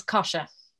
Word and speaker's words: Kosha: I hope I'm pronouncing your name correctly. – Yes, it's a weird one. It's Kosha.